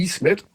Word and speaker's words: Ismet: Dass das I, das große I mit Punkt drauf, also bei Ismet, wird ausgesprochen wie das deutsche I in Miete.